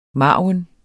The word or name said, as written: margin